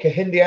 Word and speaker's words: Kehinde: Oh, Kehinde Andrews, the delightful. – That's Kehinde. One. Thank you. – Kehinde Andrews is a completely vile individual. I think we should start a campaign to defund Kehinde Andrews.